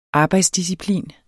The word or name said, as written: arbejdsdisciplin